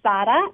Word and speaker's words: Sara: My name is Sara. It's Spanish for Sarah.